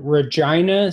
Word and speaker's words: Regina: We took a trip to Regina, Saskatchewan, rhymes with vagina, in January.